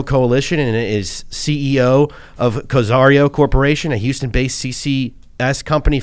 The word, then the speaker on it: Cozairo: So before I should let you know, Cozairo is how it's pronounced, okay.